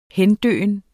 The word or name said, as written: hendøen